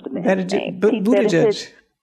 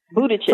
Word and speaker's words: Buttigieg: So, you know, Buttigieg, is that how you say his name? Let me look this up. – Buttigieg. – Buttigieg.